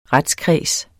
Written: retskreds

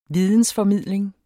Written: vidensformidling

